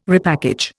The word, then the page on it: repackage: Indian